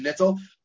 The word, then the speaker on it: Nittel: And this is Nittel with an N, like the word natal, right? N-A-T-A-L, slightly with a Yiddish pronunciation, Nittel.